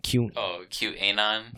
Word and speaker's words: QAnon: So the Q, or it's short for, help me pronounce this, QAnon. – QAnon.